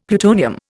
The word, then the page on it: plutonium: Indian